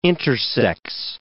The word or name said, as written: intersex